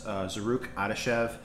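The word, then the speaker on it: Zarrukh Adashev: So Tyson Nam versus Zaruk Adashev. First time I've pronounced that guy's name right all week.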